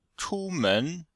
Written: chu1men2

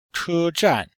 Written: che1zhan4

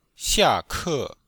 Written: xia4ke4

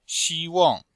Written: xi1wang4

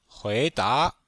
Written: hui2da2

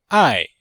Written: ai4

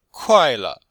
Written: kuai4le5

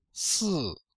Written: si4

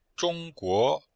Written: zhong1guo2

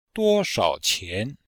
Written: duo1shao3qian2

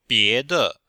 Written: bie2de5

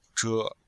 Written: zhe5

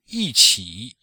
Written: yi4qi3

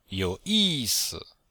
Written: you3yi4si5

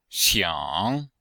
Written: xiang3

